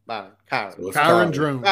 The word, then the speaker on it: Kyron Drones: Yeah, he said it's pronounced like Byron. – Kyron Drones. – Kyron Drones. Yeah.